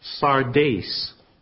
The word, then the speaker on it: Sardis: Well, the city's name is pronounced Sardes.